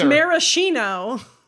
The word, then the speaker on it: Maraschino: Pronounced it Maraschino earlier, but now he's saying Maraschino. Which one is it? – What? Neither. It's Maraschino. What's happening? – I've never had to say Maraschino aloud.